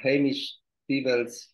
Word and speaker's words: Hamish Peebles: Yeah, thank you so much, Hamish Peebles, for your time.